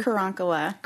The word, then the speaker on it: Karankawa: Karankawa. Am I pronouncing it correctly? – Karankawa.